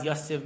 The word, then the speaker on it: Yosef: Why does Yosef mean? So really, how do you say it in Hebrew? Yosef. It's really Yosef.